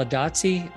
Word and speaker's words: Adatsi: We have with us today, this is going to be fun today, Sal Adazzi.